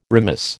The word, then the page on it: remiss: American English